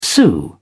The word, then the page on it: sue: British English